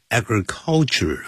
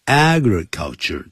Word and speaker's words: agriculture: 还有 agriculture, 不要念成 agriculture 啊，agriculture 重音在第一音节。